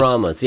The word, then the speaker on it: Brahmas: Am I saying that right? Is it Brahmas or is it Brahmas? – It's the Brahmas, yeah.